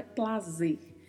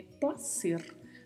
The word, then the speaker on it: placer: E não tem som de Z, porque a palavra é escrita com a letra C, então não é plazer, é placer, porque é escrito com letra C. E mais uma vez, ao final temos a letra R com o vibrato. Então pronunciamos placer.